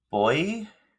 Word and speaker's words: Boii: Celberians, the Gallaecii, the Britons, the Gaul are the Gaels, the Picts, the Boii, and that's how it's pronounced, and it's spelled Boii, and the Gallatins.